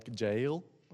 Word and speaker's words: Jael: It's spelled J-A-E-L, so it's probably more like Jael, but Jael works for me because I'm an American.